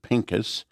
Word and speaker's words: Pinchas: This Sabbath, our portion is Phinehas. In the Hebrew, we pronounce it Pinchas.